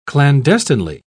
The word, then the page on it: clandestinely: British English